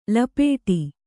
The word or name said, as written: ಲಪಾಟಿ